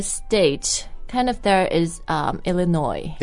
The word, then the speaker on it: Illinois: Illinois, no. No, there's no S in the pronunciation, just Illinois.